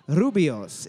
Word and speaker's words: Rooibos: To the closest 100, approximately how many calories are in a Starbucks Grande Rubos, R-O-O-I-B-O-S? I don't know how to say it. Latte, Rubio's.